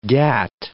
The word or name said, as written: gat